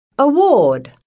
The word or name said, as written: award